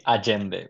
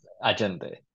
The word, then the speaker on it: Allende: I'm going to make a correction because I'm going to say Allende, which is how we would pronounce the name Allende. But I just did it now because I know all our listeners will know who Allende is, but not Allende is, unless they speak Spanish.